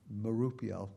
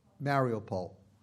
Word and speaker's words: Mariupol: So we planted congregations in Kiev, Kharkov, Lviv, and we've been working with congregations in, I always say it wrong, Mariupol. No, somebody say it right. Mariupol.